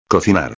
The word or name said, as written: cocinar